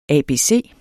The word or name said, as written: ABC